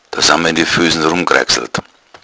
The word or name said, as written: kraxeln